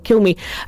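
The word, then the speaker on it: Cilmi: I said it wrong, Kilmi.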